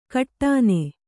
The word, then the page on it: ಕಟ್ಟಾನೆ: kaṭṭāne; kannada